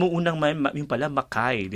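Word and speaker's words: Mackay: Pero nung una kong nabasa yan, uh, Martin, at mga kababayan, nakala ko Makmakay.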